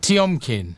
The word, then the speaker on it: Tiomkin: Okay, so now you're gonna have to help me out here because we're gonna move on to Dmitri Tiomkin. Is that how you pronounce Tiomkin. it? – Tiomkin. Tiomkin. Tiomkin.